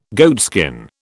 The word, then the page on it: goatskin: British English